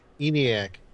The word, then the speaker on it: ENIAC: He's got an Enyaq. – Yeah, the original computer. – Yeah, geez. – Oh, ENIAC. – Oh, did I pronounce it wrong? – I knew what you were talking about.